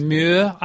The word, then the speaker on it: Ulrich Mühe: Ulrich Mühe, I think.